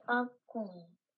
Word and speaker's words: Akmola: Speaking of Akmola, it can be spelled A-K-M-O-L-A, or you can replace the K with a Q and get Akmola, spelled A-Q-M-O-L-A. Alas, they're both pronounced the same way, Akmola.